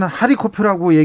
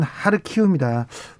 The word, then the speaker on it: 하르키우: 러시아 사람들은 하리코프라고 얘기하는데 우크라이나 발음은 하르키우입니다.